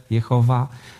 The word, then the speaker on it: Jehowa: My wiemy, że to imię po hebrajsku brzmi Jachwę, Jehowa. Różnie, różnie, różnie to jest, jest, jest odczytywane.